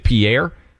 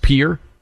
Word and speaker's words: Pierre: You know, I always had pronounced it Pierre. Did you know that, Buck? It's pronounced Pierre.